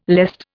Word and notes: lest: Indian

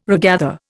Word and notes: regather: Indian

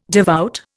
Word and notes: devout: Indian

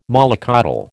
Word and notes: mollycoddle: American English

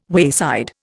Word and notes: wayside: Indian

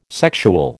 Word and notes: sexual: American English

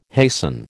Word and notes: hasten: American English

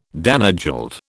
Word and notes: Danegeld: British English

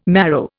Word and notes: marrow: Indian

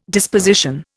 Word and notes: disposition: Indian

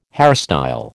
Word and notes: hairstyle: American English

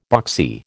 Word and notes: boxy: American English

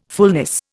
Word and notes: fullness: Indian

